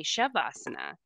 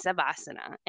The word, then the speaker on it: Savasana: And I said, is that how you say it? Because I've heard people say Shavasana. And she says, oh, no, it's Savasana.